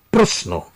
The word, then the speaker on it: প্রশ্ন: এমন ক্ষেত্রে উচ্চারণের সাধারণ নিয়ম হল শব্দের শেষে যুক্ত ব্যঞ্জন থাকলে শব্দ শেষের অয়ের উচ্চারণ ওকারের মতো হয়। আপনাদের সুবিধার জন্য শব্দগুলোর উচ্চারণ আরো একবার বলছি, অনুরক্ত, যত্র, প্রাপ্ত, প্রশ্ন। প্রশ্নকে অনেকে প্রশ্ন উচ্চারণ করেন, এটি প্রমিত উচ্চারণ নয়।